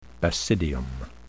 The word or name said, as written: basidium